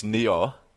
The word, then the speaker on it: Nioh: Which has a different pronunciation that he just realized. – Yeah, so it's Nioh. N-I-O-H.